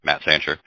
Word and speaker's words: Matt Santjer: On Facebook is the business page, and then also my personal Facebook is Matt Sancher. And Sancher is spelled Santjer. Probably not the easiest last name to spell, but there's not a whole lot of other Sanchers out there that I know of.